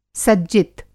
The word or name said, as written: सज्जित